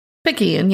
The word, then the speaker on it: Picayune: Picayune, yeah. – Picayune? Is that how that's pronounced? – Yep, I don't know what it is.